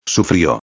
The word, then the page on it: sufrio: espanhol